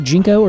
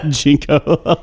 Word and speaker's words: ginkgo: Is it pronounced Jinko or Ginkgo? – Jinko, like the pants.